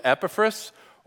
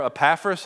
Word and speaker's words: Epaphras: The person who scholars believe did start this church was a guy by the name of Epaphras, or Epaphras, as some people might pronounce it, and we're going to hear about him in a little bit.